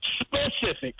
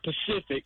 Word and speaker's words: specific: Specific, like being specific about something. – Yeah, I know. – They always say Pacific.